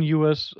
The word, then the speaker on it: Ewers: Ja, Quinn Ewers, so wird es im Amerikanischen dann ausgesprochen. Jetzt will ich schon ins Englische wechseln.